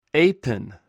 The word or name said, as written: eipen